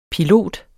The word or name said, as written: Piloten